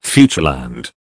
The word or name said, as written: Futureland